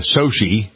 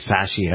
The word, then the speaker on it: Sochi: Not the winter weather here, but the winter weather in the area of Russia, Sochi. Some people are saying Sashi. I don't know why they do that, because it's spelled S-O-C-H-I.